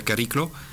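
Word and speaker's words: Chariklo: Y incluso un asteroide, Cariclo, perdón, se llama Cariclo, se escribe con CH, pero se pronuncia Cariclo, tiene anillos también que se han podido observar.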